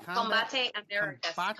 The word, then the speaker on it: Combate Americas: Combate Americas.